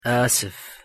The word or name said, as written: excusez-moi